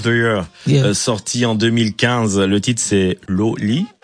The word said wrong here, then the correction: Lole: Comment tu dis? How do you pronounce it? – Lole. – Lole. – Ok, Lole. Ouais.